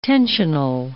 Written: tensional

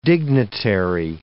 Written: dignitary

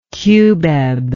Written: cubeb

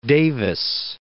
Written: Davis

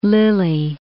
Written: Lyly

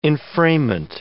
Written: enframement

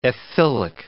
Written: ethylic

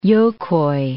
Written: Jokai

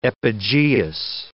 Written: epigeous

